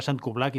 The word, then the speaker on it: StQBLK: Això s'escriu d'una manera una mica rara, que és S-T-Q-B-L-K. Has de pronunciar Sant Cublac i, si no, doncs així és com he pensat que valia la pena pronunciar-ho.